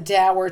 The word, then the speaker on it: dour: The key thing is, I mean, it came into English via, you know, the Scots and Northern English into American English. And they would pronounce it doer, you know, and mm-hmm. Americans tended to pronounce it dour, and now it's going back and forth. – Huh. – So both pronunciations are in Merriam-Webster.